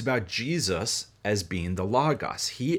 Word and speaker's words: logos: So in Greek, there is a word logos, L-O-G-O-S. Looks like the word logos, but it's pronounced logos.